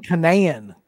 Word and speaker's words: Kanan: So his dad, Sean Kanan. – Sean Kanan. There you go. – Yeah. – Keenan. – Yeah. I think that's how you pronounce it. I don't know why, because it's not spelled that way.